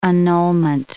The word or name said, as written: annulment